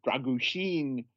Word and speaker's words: Dragušin: As for Dragušin, or as the BBC pronounced him today to rhyme with Martin Sheen, Dragušin,